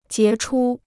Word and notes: 杰出: jié chū

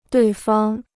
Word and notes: 对方: duì fāng

